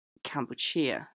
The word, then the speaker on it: Kampuchea: to the Khmer pronunciation of the word, Kampuchea.